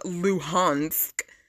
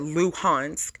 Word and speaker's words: Luhansk: Um, who is a Moscow appointed head of Luhansk, I think that's how you pronounce it, Luhansk,